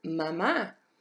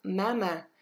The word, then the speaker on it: maman: The next one we have is maman. M-A-M-A-N. So you notice we don't actually say the N at the end. C'est juste maman. And we close that A sound. Après ça, c'est papa. P-A-P-A.